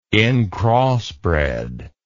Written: incrossbred

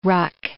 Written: ruck

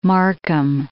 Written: Markham

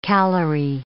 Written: calorie

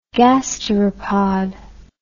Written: gasteropod

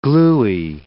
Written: gluey

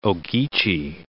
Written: Ogeechee

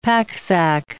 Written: packsack

